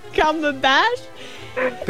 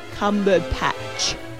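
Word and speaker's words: Cumberbatch: It's not Cumberbash. – Benedict Cumberbash. – Benedict Cumberpatch. Patch.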